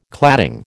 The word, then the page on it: cladding: American English